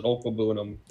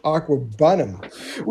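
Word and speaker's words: Okwuegbunam: It's I still can't pronounce it, and I've been watching him for 2 years. It's Okwabunam, I want to say is how you pronounce it. Okwabunam.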